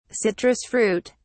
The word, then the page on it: citrus fruit: British English